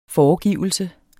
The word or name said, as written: foregivelse